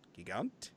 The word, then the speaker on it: Gigant: It's Ray and then G-I-G-A-N-T. Is it Gigant? – Gigant. – Gigant. – Yeah, that sounds good.